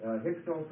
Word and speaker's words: Hyksos: Hyksos is spelled H-Y-K-S-O-S.